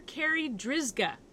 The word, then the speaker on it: Carri Drzyzga: Dr. Carri Drzyzga.